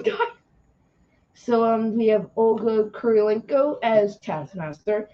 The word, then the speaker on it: Kurylenko: Kurylenko. And you said it right. Olga Kurylenko. Yep.